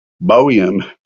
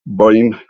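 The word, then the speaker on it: Boehme: Jacob Boehm. Okay, that's the Texas way of pronouncing it. But no, it's German, so I think it's Jacob Boehm, something like that, something close to that.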